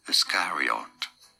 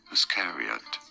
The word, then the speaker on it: Iscariot: In British English, this is said as Iscariot. Now, in American English, it is generally pronounced as Iscariot.